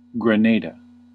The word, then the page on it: Grenada: [ɡɹɪ.ˈneɪ.də]; /ɡɹəˈneɪ.də/